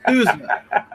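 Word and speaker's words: SUSE: Sushi, Ubuntu. – First of all, can we just correct your pronunciation of Sushi, man? This is not fish, it's Sousa. Open Sousa.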